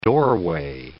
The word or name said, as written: doorway